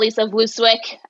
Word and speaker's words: Vlooswyk: Well, Lisa Longball, Lisa Vlooswyk.